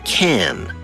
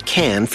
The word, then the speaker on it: Cannes: Even though it is spelled C-A-N-N-E-S, it is pronounced Cannes, not Cannes or Cons.